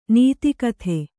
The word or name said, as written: ನಿದಿಗ್ಧ